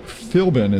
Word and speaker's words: Philbin: Jeff French and Mike Philbin is how you say it. – Correct.